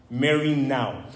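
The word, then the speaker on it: merinou (μεριμνάω): In Greek, the root word for anxiety or for worry or concern is this word pronounced merinou. Can you guys say merinou?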